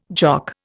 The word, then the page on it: Jock: Indian